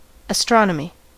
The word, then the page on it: astronomy: American English; GenAm; /əˈstɹɑnəˌmi/